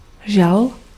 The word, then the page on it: chagrin: /ʃa.ɡʁɛ̃/; France; /ʃa.gʁɛ̃/